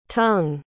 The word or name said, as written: tung